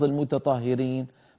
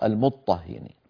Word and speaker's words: المطهرين: إذن المطهرين أصلها المتطهرين، ولا تلفظ المتطهرين بل تلفظ المطهرين.